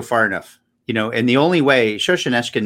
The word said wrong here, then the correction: Solzhenitsyn: makes this point in his book. – Solzhenitsyn. – Alexander Solzhenitsyn.